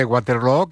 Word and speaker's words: Waterloo: Pues no lo es, desde luego, pero Aba ha hecho mucho daño en la pronunciación de Waterloo, que se pronuncia igual que Feijóo.